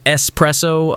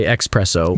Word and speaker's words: espresso: Instead of pronouncing it espresso, a lot of people say expresso, which sounds almost criminal.